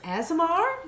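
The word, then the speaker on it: Aasimar: She is an I'm going to mispronounce this. Asimar? – No, you got it.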